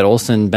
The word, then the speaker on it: Olson: By the way, my name is spelled with an O, O-L-S-O-N. A lot of people think it's spelled with an E for some reason.